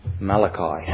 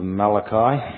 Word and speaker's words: Malachi: So if you turn with me to the book of Malachi, pronounce Malachi, not Malachi, as some would like to use.